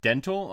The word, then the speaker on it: Dntel: And, DJ who goes by Dental, I'm assuming is how it's pronounced, is D-N-T-E-L.